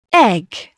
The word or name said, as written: egg